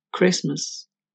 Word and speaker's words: Christmas: Y en la palabra Navidad, Christmas. También está escrita y no se pronuncia.